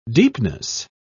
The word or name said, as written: deepness